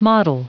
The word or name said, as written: mottle